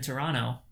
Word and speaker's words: Toronto: someone's not from Toronto if they pronounce the T in Toronto, which you just did, by the way.